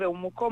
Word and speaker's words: Umuco: Sì, un progetto Umuco, si scrive Umuco ma è Umuco.